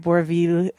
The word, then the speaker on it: Bourvil: Stars Alain Delon, André Bourvil, that's how you say it, and so on.